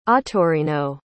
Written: ottorino

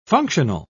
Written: functional

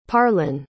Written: parlan

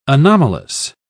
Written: anomalous

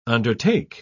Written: undertake

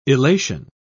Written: elation

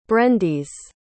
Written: brendis

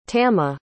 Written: tamah

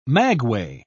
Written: maguey

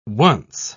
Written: once

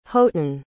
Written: houghton

